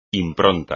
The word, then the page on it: impronta: espagnol